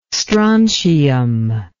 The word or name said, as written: strontium